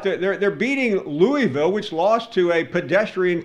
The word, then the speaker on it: Louisville: At least pronounce it right. it's Louisville.